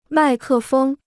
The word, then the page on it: 麦克风: mài kè fēng